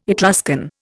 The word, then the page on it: Etruscan: Indian